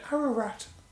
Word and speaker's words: Ararat: You said Mount Ararat. – Yeah. – I'm afraid the correct answer is Mount Ararat. – Oh, for fuck. Fucking syllable out. – Yes, you were a syllable out.